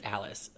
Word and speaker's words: Alys: Alice Elaine Rankin, I believe it's Alice. It's spelled A-L-Y-S.